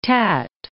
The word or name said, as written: tat